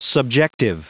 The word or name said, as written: subjective